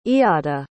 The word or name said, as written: eadda